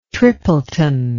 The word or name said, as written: tripleton